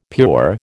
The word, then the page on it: pure: American English